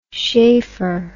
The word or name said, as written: sheaffer